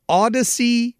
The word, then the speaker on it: Audacy: And all you have to do is download it at Odyssey. It's the Odyssey app. A-U-D-A-C-Y.